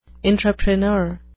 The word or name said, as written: intrapreneur